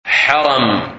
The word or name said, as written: Haram